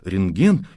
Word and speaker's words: рентген: И в 99 случаях из 100 произносят это слово как «рентген» с ударением на последнем слоге. Именно с этим ударением слово «рентген» напечатано в словаре Ушакова. Очевидно, такое произношение надо считать правильным, литературным.